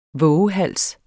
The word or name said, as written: VOVEHALSENES